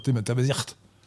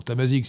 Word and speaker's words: Tamazight: Je prononce T-A-M-A-Z-I-G-H-T, on devrait dire tamazirt. Bon, Tamazique si vous voulez.